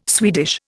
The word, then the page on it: Swedish: Indian